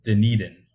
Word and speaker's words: Dunedin: Dunedin? I always like to repeat the fact that it is pronounced Dunedin for those listening.